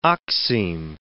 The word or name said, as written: oxime